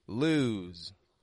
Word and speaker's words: lose: It's not quick like lose. Lose. But it's lose. It stretches out in the vowel sound. And then it voices that S as a Z.